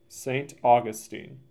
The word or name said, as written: St. Augustine